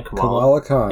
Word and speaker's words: Kamala Khan: Is that her name? – Kamala Khan. Yes. That's right.